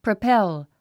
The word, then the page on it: propel: prəpél; プロペェゥ